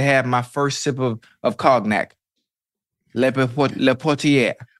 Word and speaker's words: cognac: Okay, you said cognac, but it's spelled C-O-G-N-A. Why not a Y? Because you're saying yak. Cognac. So it should be C-O-N-Y-A-C.